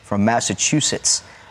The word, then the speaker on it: Massachusetts: You're a transplant all the way from Massachusetts. And did I say that correctly? – Yes. – I did. I got it. – No T in between.